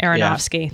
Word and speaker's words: Aronofsky: Darren Aronofsky, Black Swan guy. – You guys know who I mean. I think. – Aronofsky, yeah. Thank you. Yeah. Let's just call him Black Swan guy. Aronofsky.